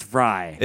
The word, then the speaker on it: Frey: Frey, pronounced Fry. – It's Fry like Glenn Fry. Spells it the same way.